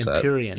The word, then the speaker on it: Empyrean: I don't know how to pronounce Empyrean. – That. Empyrean. Yeah.